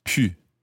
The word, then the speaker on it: pu: Now, notice the pronunciation of that. OU in the infinitive sounds like pouvoir, pouvoir. EU in the present tense sounds like je peux. And now we have you on its own in the past participle, and that's eu, pu.